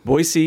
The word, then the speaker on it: Boise: Boise, yes, with the S, not a Z.